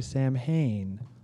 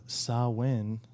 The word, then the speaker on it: Samhain: Yes, pronounced as a lot of people say Samhain, but the correct pronunciation Gaelic people fucking hate that. – Is Samhain. – So Samhain.